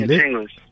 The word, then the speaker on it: Nyachengos: Am I saying it right? The name Nyachengos, is that right, Auntie? – Nyachengos.